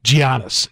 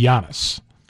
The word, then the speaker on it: Giannis: Like Kareem, did he even, somebody go through with him and say, now it's Giannis, or, you know, somebody had to know that he pronounces it Giannis and that it should be Giannis.